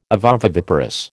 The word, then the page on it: ovoviviparous: American English